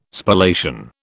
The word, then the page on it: Spallation: British English